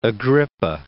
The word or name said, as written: Agrippa